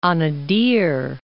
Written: Gulf of Anadir

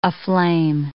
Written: aflame

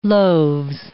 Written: loaves

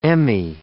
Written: Emmy

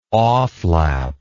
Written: offlap

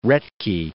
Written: Roethke

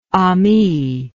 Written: ami

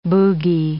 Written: boogey

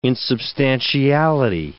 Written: insubstantiality

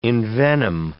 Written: envenom

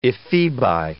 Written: ephebi

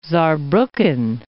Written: Saarbrucken

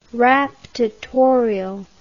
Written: raptatorial

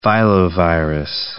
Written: filovirus

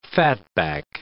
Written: fatback

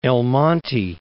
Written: El Monte

